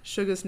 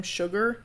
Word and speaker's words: Suga: And one guy was trying to, like, remember Suga's name and called him Sugar instead. He said it correctly the first time, and then the next two times he said Sugar.